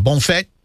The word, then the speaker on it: bon fête: How do I say that? Bon fête? – Yes, you got it.